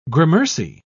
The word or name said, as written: gramercy